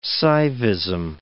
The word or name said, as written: Saivism